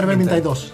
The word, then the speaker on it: Vermintide: Vermintide 2.